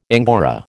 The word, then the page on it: angora: American English